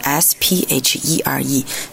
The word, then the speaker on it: sphere: s p h e r e sphere 发的时候，因为有一个 f 的音有点难。